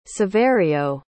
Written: saverio

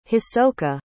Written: hisoka

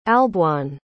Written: alboin

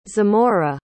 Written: zemora

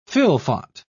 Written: fylfot

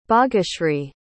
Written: bageshri

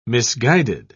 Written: misguided

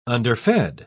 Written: underfed

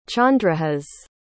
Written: chandrahas

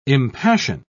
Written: impassion

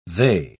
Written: they

